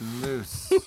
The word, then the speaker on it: Luz: L-U-Z, motherfuckers. I'm Puerto Rican. Spell it like you know some Spanish. – It's loose.